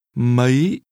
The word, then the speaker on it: mấy: Mấy? Mấy? Mấy giờ? – How many is pronounced with a high rising tone.